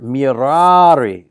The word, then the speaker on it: mirari: Miror, mirari. So it's a long A that identifies it as first conjugation.